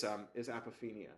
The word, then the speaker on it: apophenia: 95% of what we see through the fMRI is apophenia. It's pattern. It's our brains imagining patterns. – Apophenia. How do you say it? – APOPHENIA.